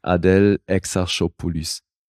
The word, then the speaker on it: Adèle Exarchopoulos: Da, ja, die hat einen fiesen Namen, weil das ist halt ein griechischer Name, der französisch ausgesprochen wird. – Und, hast du es drauf? – Adèle Exarchopoulos.